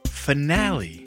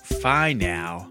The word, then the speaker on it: finale: BeeBop, I'm only jumping in to let you know that I think you're mispronouncing that word. It's finale, not... Fine now.